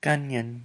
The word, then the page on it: kanyen: Lwaitel